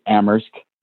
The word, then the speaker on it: Amherst: It's actually Amherst, Mass. – Oh, excuse me. Excuse me. – No, that's okay. Only the H is silent in Amherst.